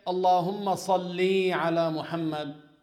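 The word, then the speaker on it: Salli (صلِّ): Salli. Lam with kasra only. There is no ya after the lam. Salli. Allahumma salli ala Muhammad. This is how you say it.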